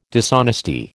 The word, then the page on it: dishonesty: American English